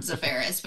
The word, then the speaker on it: Zafarris: Z-A-F-E-R-I-S. Two different spellings, we smashed them together into the same pronunciation, Zafaris, but with my spelling.